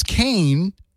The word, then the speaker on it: Kane: And speaking of music, it rhymes with Bane, but it's not Bane. It's Kane.